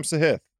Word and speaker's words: Sahith: If you parlay Cam Young, Cam Davis, what did I say? I said it right. Cam Sahith. That's right.